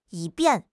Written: 以便

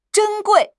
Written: 珍贵

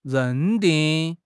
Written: 认定

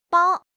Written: bao1

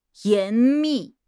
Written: 严密

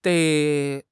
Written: dei3

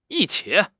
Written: yi1 qi3

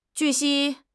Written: 据悉

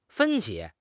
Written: 分歧

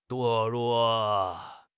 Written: 堕落

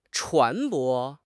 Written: 船舶

